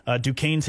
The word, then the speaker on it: Duquesne: Duquesne is spelled D-U-Q-U-E-S-N-E. I found out today you say it Duquesne.